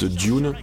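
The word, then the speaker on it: Dvne: Ensuite Dune, qui s'écrit D-V-U-E, D-V-N-E.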